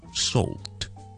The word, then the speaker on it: salt: 这个发音 Number one，首先我哋读咗个 salt 字先，好似我哋读用眼睇 C 嗰个 past tense saw，S-A-W 咁 saw，跟住我加一个 L 音，即系啦啦啦嗰个 l 呢个音，咁咧就会读成咧 saw，跟住我哋用一个 T 音完结佢。